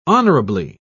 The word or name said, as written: honorably